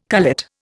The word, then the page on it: cullet: Indian